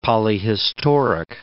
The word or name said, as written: polyhistoric